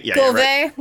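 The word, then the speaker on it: Cuvier: And then George Culver, he's French, so I'm not pronouncing it correctly. – Culver.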